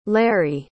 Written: lary

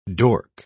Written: dork

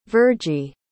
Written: virgie

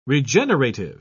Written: regenerative

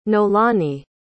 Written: noelani

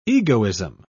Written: egoism

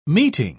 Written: meeting

